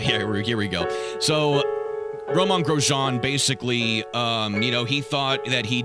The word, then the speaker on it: Grosjean: Meanwhile, they bring in Grosjean. I'm pronouncing his name right, right? – Yes, you are.